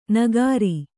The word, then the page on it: ನಗರಿ: Kannada